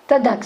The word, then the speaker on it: Tanakh: Ben oui, on ne vocalise pas, mais essayez de prononcer T-N-K. Voilà. Tanakh, ça marche?